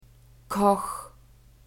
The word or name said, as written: Koch